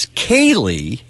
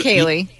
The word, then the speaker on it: Ceilidh: I've never known Kaylee. – Usually it's K-A-Y-L-E-I-G-H is usually how you spell Kaylee, I think. – C-E-I-L-I-D-H. – What? – C-E-I-L-I-D-H. – Well, you got that famous silent D.